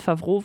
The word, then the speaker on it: Favreau: Ich weiß jetzt auch, wie er ausgesprochen wird mit Favreau, weil er äh, äh, bei Wikipedia steht tatsächlich auch die Lautschrift dabei.